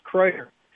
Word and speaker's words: Kreuter: It's Kreuter. It is Kreuter. Kreuter. Although Quate sounds more fancy, but it is Kreuter, yes.